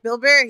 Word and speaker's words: Bill Burr: The first Bill Burr episode, that's how you say his name, Bill Burr? – Yeah, Bill Burr, yeah.